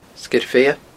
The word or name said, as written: Sgeir Bheithe